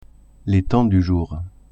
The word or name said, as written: temps du jour